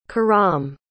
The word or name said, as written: karam